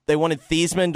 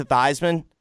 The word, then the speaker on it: Theismann: Like, at a certain point, they wanted Theismann to rhyme with Heisman, so they changed it to Theismann, but really his name is actually Theismann? – Is it?